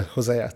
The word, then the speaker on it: Hosea: Hosea. Did I say it wrong? – Yeah. No, you got it.